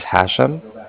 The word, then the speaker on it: Hashem: My name is Hashem. That's H-A-S-H-E-M with an M, like mother at the end.